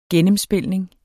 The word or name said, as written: gennemspilning